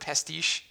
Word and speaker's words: pastiche: I'm going to say the word pastiche. – Yeah. – I was told that that's the correct way to say it.